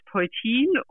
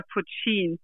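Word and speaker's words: poitín: Also das, das wird ausgesprochen, also Deutsch Poltin oder Putschin.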